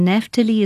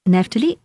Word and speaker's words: Naphtali: It's spelt, Rishon is R-I-S-H-O-N. Rishon, and Naphtali is N A P-H, P for Peter. So it's Naphtali, N-A-P-H-T-A-L-I.